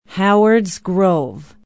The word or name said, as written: howards grove